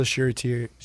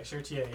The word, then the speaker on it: Charretier: Elsa Charretier. – Okay, Charretier, yeah.